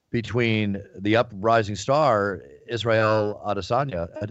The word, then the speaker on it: Adesanya: Did I say his name last correctly? – You'll say Adesanya. It right on Saturday, and I am in no way prepared to tell you how to say it right now. – I will say it correctly on Saturday or Friday night. – I think you got it right, to be honest with you.